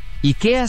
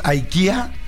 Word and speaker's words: IKEA: En sueco, IKEA se pronuncia IKE con acento agudo A. – IKE. Entonces, IKE en Suecia y seguramente en Europa ha de ser IKE. En Estados Unidos, IKEA. Y en México muchos decimos IKEA.